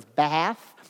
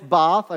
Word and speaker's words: Bath: You may have heard of it as Bath. Okay, it's not how it's said. It's Bath. Okay, that's the English pronunciation, the American pronunciation, Bath. But no, it's Bath.